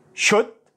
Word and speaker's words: should: should walk talk could would ال لا ينطق would